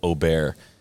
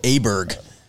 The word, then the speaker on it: Åberg: Ludvig Åberg. Huh. See, I'm committed now to doing the Swedish pronunciation of Cody Åberg.